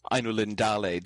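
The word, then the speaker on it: Ainulindalë: Often in Elvish words, you put the stress on the second to last syllable, but there it doesn't work with the whole thing. Ainu Lindale doesn't really work. The whole thing's a compound word. You tend to put the stress on the first and the third syllable there because that's where they would fall naturally on those words independently.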